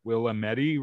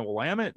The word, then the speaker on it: Willamette: It's just been generationally heard, but somebody comes around and they're like, Willamette River, is that the one I need to go fish at? It's like, oh, you mean the Willamette? They're like, that's how you say it.